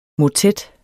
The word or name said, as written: motet